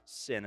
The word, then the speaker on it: syn: S-Y-N, that fuses you together, fuse, like welding together with God in Christ. So the S-Y-N is from the Greek, which would actually be sigma upsilon, looks like a U, nu. But it's pronounced sin. It's printed in English, S-Y-N.